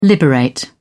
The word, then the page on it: liberate: British English